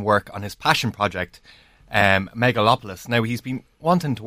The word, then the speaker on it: Megalopolis: Okay, Megalopolis.